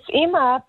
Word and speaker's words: Ima: And can you tell us about Ima, I-M-A? Is that how you say her name? – It's Ima